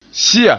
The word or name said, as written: siya